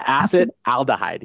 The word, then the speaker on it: acetaldehyde: I don't even know if I'm going to pronounce this right, but acetaldehyde? – Yeah, acetaldehyde, yeah.